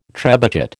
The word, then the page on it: trebuchet: American English